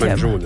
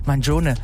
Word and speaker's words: Mangione: Der wird Mangione. Ja immer, Mangione.